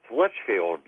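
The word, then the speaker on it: Leitchfield: Hey, it's Litchfield. – Litchfield. George, right. But it's spelled L-E-I-T, right? – Yeah, I always call it Litchfield.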